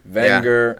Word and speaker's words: Wenger: Just pretend it's a V. – Wenger.